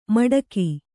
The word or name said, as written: ಮೆಟ್ಟಿಕೆ